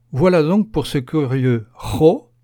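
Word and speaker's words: ĥo: Le H se prononce en expirant un peu d'air, comme dans l'anglais hat, chapeau. C'est donc la lettre ho.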